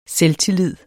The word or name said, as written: selvtillid